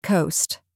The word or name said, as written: coast